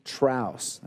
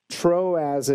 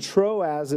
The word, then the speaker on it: Troas: And last week, if you were with me, I was pronouncing the city Traus. That's just that I've been in New Mexico too long, and I think of Taos and throw an R in there, but that's not how you pronounce it. Troas is how you pronounce this town.